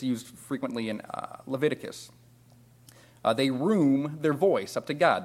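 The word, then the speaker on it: room (רום): The Hebrew word for exalt is room. That's spelled R-U-M, but not pronounced like R-U-M.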